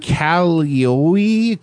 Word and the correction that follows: Caillou: I don't know how to say it. C-A-I-L-L-O-U. – It's called Caillou.